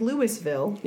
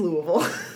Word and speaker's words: Louisville: But it does for all of the rest of the country look like Louisville. Yeah. But it is Louisville.